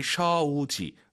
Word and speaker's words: 手机: 마이 쇼지 그리고 쇼지가 아니라 오유는 어우니까 마이 쇼우지 마이 쇼지 들어볼게요.